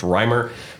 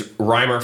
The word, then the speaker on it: Reimer: Uh, his last name pronounced Reimer. For those of you who were listening uh, last week, I believe I said it wrong last week, but I've since confirmed it is Reimer, former uh, paramedic.